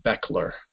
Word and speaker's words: Bechler: Yeah, and go ahead and spell your name for people, just so that they the last name is Bechler. So it looks like Bechler, but it's pronounced Bechler. And my first name is Jamy, but it's spelled J-A-M-Y.